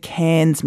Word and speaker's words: cairn: Cairns mean? Am I saying it right? Cairns? – Cairn.